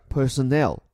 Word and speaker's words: personnel: 这里又出现了 detain，他拘捕的对象 personnel，这个词的发音跟 employee 相似啊，也是重音放车尾，而且意思也很像，它表示的是员工职员，有所不同，这个词是一个复数概念，就说它通常后面不用再加 s。